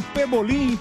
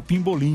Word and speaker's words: Pebolim: Eu descobri que eu sempre falei errado. – Falou errado, você fala Pimbolim.